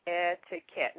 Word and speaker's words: etiquette: So, since it comes from a French word meaning ticket, that is the reason that the Q sound actually adopts the sound as the K. So, in knowing that, you will not mispronounce that word anymore. So it's etiquette.